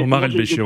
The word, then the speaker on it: Omar El-Béchir: Donc, je pense que c'est un pays qui a été trop fermé depuis très longtemps par rapport à Bachir. – Ouais, je ne sais pas si je prononce bien le nom du président, je m'excuse si je... – Mais, Omar El-Béchir, oui.